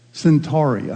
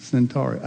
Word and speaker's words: Centauri: Centauri, I'm sorry, I'm mispronouncing it. Centauri, Alpha Centauri.